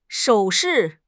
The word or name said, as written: 手势